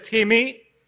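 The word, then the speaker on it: Timmy (τιμή): And the word for honor in Greek is a word that is spelled out literally like the word time, but with two E's at the end. So you could say like Timmy.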